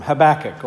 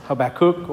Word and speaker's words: Habakkuk: One of those opportunities to reflect on briefly a book that we don't often hear from, Habakkuk or Habakkuk or Hey Backpack, as Gary helped someone remember how to pronounce it on Sunday.